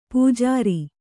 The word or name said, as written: ಪೂಜಾರಿ